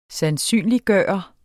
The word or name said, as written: sandsynliggøre